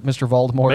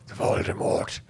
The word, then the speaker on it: Voldemort: Voldemort. No, Muggle. – Even better would be like more. – Yeah. – You know, because you're not supposed to pronounce the T.